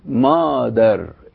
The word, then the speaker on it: مادر: يعني عندنا كلمة بيدر، بيدر معناها الأب، عندنا كلمة مادر، مادر نفس الكلمة الإنجليزية مازر، بس دي بننطقها ازاي؟ ميم ألف ودال وراء، واحنا قلنا ان الألف لما ما بتبقاش الحرف الأول بتنطق مفخمة، عشان كده بنقول فيها ايه؟ مادر، مادر.